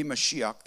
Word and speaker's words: Mashiach: We say that word, and you hear that word, and that word, I guess, if we were trying to pronounce it, it'd be something like Mashiach. Okay, it'd be Mashiach.